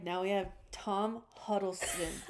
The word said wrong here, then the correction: Hiddleston: It's supposed to be Hiddleston.